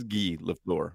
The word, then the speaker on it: Guy Lafleur: And and then in the NHL, it's written Guy Lafleur, but I think it's Guy Lafleur. – It right? is Guy Lafleur.